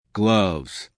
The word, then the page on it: gloves: American English